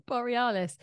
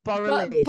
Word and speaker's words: Borealis: Borealis. – Aurora Borealis.